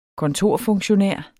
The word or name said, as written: kontorfunktionær